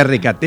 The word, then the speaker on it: RKT: ¿Pero se dice RKT? – Exactamente, sí, se dice así.